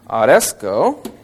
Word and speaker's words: aresko: We have the Greek word aresko. A-R-E-S-K-O, long O. The majority of the Greek verbs, by the way, end with a long O sound, which is the omega, the final O. This word Oresko is also is a present active participle,